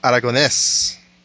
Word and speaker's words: Aragonés: Aragonés. Emphasis on the last syllable.